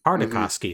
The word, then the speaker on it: Tartakovsky: Gennady Tartakovsky. Mm. – Tartakovsky, mm-hmm.